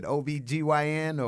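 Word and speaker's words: OB-GYN: Is it OBGYN or? – That's perfectly correct.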